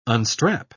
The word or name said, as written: unstrap